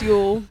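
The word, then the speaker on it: Jól: But it's spelled J-O-L, and I don't speak Icelandic or German. – It's just pronounced Yule. – Oh, okay, perfect. Well, it's Yule in English as well.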